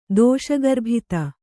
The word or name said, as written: ದೋಷಗರ್ಭಿತ